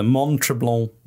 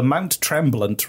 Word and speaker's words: Mont Tremblant: The Mount Tremblant Resort. – Nope. – Sorry, I said it right the first time. Ignore that second time.